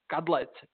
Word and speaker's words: Kadlec: And my name is Josef Kadlec. But as you said, Cadillac actually I kind of use it as my nickname as well for American and Canadian folks to, to pronounce my name.